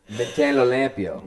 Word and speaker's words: mantenlo limpio: Contendio en limpio? I said that wrong. Dad, how do I say it? You corrected me last time. Dude, how do I say it? – Mantenlo limpio. – Yeah, mantenlo limpio.